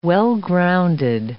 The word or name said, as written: well-grounded